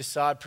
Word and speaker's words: Sade: So this is a picture of the only picture, actual picture of Marquis de Sade, pronounced Sade, not Sade or Sade or anything like that.